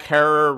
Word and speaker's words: Herer: You just have to find them on Dead Lot, and they tell you the whole Jack Herer rhymes with terror book from start to finish.